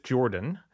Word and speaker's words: Giordano: Judith Giordano, G-I-O-R. – Ironically, pronouncing her name is more difficult than quantum mechanics. – Judith Jordan.